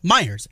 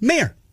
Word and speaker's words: Meyer: There are Meyers, M-E-Y-E-R. – Uh-huh. – Yet a portion of the family pronounces it Mayer. The other part pronounces it Meyer.